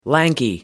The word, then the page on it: lanky: American English